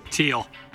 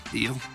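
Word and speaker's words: Thiel: Teal. – Try to activate him. – Teal.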